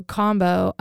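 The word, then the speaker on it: kambo: So combo, I think I'm saying that right. It's K-A-M-B-O.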